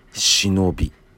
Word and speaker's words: 志能便: てて、この、えー、役職名、役職っていうのかな、まあ、肩書きが忍び、えー、言われてるんです。でこれ漢字は、えーと、とその忍者の忍とかではなくて「えー、志す」「能力の能」に「郵便の便」って書いて「忍び」と読みますね。